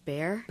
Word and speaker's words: Bexar: The county commissioners in Bexar County, Texas, have proposed... – Bexar County. – It's my understanding it's pronounced Bear.